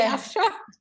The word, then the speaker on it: Afshar: Believe me, in Iran, everyone says my last name wrong too. It's hard to say Afshar.